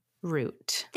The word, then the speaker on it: route: How do you pronounce R-O-U-T-E? – Root. – Thank you. – Not route. – Thank you. That's it. It's root.